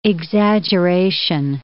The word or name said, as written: exaggeration